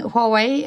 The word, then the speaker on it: Huawei: Jeg sier nå Huawei. Eh, jeg sa nok Huawei eh, før jeg begynte. – Så jeg har progresjon, tror jeg. Der, eh, men Huawei er, sånn jeg har valgt å uttale det nå.